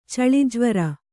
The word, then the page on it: ಛಳಿಜ್ವರ: chaḷi jvara; Kannada